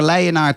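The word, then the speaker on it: Leidenaar: Nou, eh, nou, ben jij een geboren Leienaar, toch? Zeg ik dat goed? Eh, ik ben geboren in Leien, ja.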